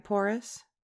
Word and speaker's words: Porras: I think that's right, Porras. It's probably like, probably got to roll the R. – Carmen Cindy Porras.